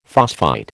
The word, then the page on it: phosphite: American English